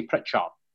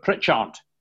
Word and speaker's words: Pritchard: Now, the D sound I pronounce, because I'm a commentator on this, about the assonance of the word, right? So people expect me to say Pritchard, right? Just soft. But Pritchard with a hard D, and they thought it sounded like an S.